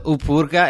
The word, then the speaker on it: Upurga: It's called Upurga. Am I pronouncing the title right? – You cannot spell it wrong. Of course, it's right. – Upurga.